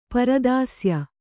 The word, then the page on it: ಪರದಾಸ್ಯ: Kannada